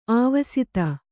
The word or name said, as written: ಆವಸಿತ